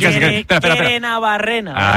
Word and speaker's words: Gerenabarrena: Gerenabarrenera, ¿no? – Bueno, pues casi. – Gerenabarrena. – Ahí está.